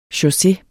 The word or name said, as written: chausses